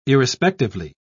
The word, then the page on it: irrespectively: British English